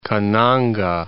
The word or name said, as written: Kananga